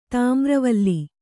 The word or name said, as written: ತಾಮ್ರವಲ್ಲಿ